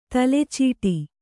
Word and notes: ತಲೆಚೀಟಿ: tale cīṭi

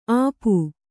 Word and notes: ಅಪು: Kannada

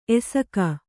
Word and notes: ಎಸಕ: esaka

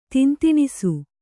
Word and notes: ತಿಂತಿಣಿಸು: Kannada

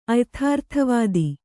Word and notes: ಅಯಥಾರ್ಥವಾದಿ: kannada

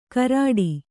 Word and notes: ಕರಡಿ: karaḍi

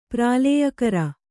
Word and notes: ಪ್ರಳಯಕರ: praḷayakara; kannada

